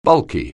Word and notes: bulky: anglais

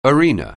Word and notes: arena: anglais

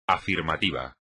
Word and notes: afirmativo: espagnol

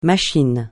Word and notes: machine: francais

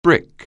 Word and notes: brick: anglais

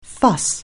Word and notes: fuss: anglais

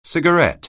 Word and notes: cigarette: anglais

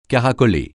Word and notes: caracoler: francais

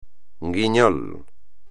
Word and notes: guiñol: espagnol

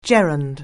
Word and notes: gerund: anglais

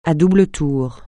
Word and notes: à double tour: francais

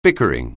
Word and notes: bickering: anglais

